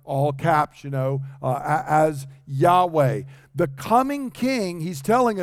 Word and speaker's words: Yahweh: Many times in your English Bibles, if you look and you see Lord, not capital L, lowercase O-R-D, but all caps, L-O-R-D, that means they are translating the covenant name of God, this Y-H-W-H, which we sometimes again pronounce as Yahweh.